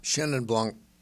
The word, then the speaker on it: Chenin Blanc: And it's in the, how do you say the word of this wine? Chenin Blanc? – Chenin Blanc.